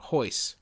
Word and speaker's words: Royce Gracie: Like Royce Gracie. His name is spelled R-O-Y-C-E, but it's pronounced Hoyce. – I did not know about the R-N-H thing.